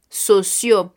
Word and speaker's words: sociaux: Y enseguida tendremos sociales, pero se escribe de la siguiente manera en francés. S, O, C, I, A, U, X socio.